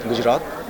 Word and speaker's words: Gujarat: Gujarat.